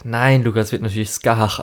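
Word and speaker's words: Scáthach: Und es ist natürlich auch hier irische Mythologie und darum, das wird halt Skahach ausgesprochen. Aber okay.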